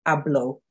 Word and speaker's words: hablo: So hablo means I speak. Hablo. And you'll notice that the H is silent. If you have watched my phonics video, you'll know that an H is usually silent in Spanish.